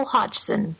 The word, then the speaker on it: Hodgson: So, Joel Hodgkin, am I saying his name right? Joel Hodgkin? – It's Joel Hodgson.